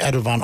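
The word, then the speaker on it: Erdoğan: Der wird ja wirklich Erdogan ausgesprochen, ne? In der Türkei.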